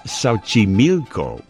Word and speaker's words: Xochimilco: But now it's Xochimilco. Xochimilco, which is spelled X-O-C-H-I-M-I-L-C-O.